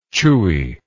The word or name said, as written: chewiness